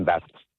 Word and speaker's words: Bathurst: Yeah, it's always been Bathurst.